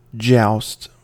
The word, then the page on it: joust: American English